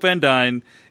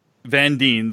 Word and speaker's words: Van Dyne: So I just wanted to call out, like, it is Hope Van Dyne. It's not pronounced Van Dyne the way Casper Van Dyne's name is pronounced.